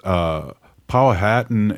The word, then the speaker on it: Powhatan: We have someone saying it's Powhatan, and it's a Virginia tribe.